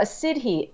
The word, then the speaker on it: siddhi: A siddhi, S-I-D-D-H-I, is a miraculous ability, or an ability that seems miraculous.